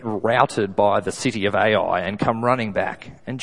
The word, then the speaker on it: Ai: And then next city on the list is Ai, which I think is how it's pronounced.